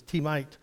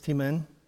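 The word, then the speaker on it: Temanite: Eliphaz, the T-man, I've always pronounced it as T-mite, but it's T-man, there we go.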